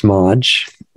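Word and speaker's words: Maj: I was saying it wrong before Madge, but it's Madge.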